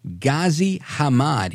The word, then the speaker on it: Ghazi Hamad: It's Ghazi Hamad.